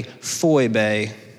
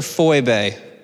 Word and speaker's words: Phoebe: Uh, fun anecdote in the Greek, the way that you pronounced Phoebe is actually Phoebe.